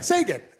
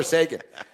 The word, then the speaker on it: Seguin: And also with Seguin. Yeah, it's not like the Penguin. I know it's spelt like that. – Seguin? – Yeah. – Sagan. – Yeah, it's Tyler Sagan.